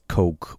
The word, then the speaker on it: Koch: In die zweite Kategorie gehört die Kochfamilie wird wie Koch geschrieben, auf Englisch als Coke ausgesprochen.